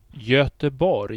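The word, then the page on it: Göteborg: ˌʝœtəˈbɔrj; Swedish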